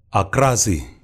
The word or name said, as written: Akrasi